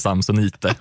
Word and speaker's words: Samsonite: Ja, och där har vi en till uttalsklyfta här, tror jag. Det kan ju omöjligt vara så det uttalas. – Samsonite. Jag tycker det. Som svensk charterturist heter det Samsonite och inget annat.